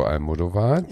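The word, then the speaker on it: Almodóvar: Okay, dann hüpfen wir mal zu Pedro Almodovar, sie hat das richtig ausgesprochen. Wir sind so stolz auf dich.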